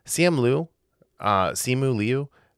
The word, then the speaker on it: Simu Liu: Like Simu Liu. How do you say it? Mm-hmm. Did I say it right the second time? – Yeah.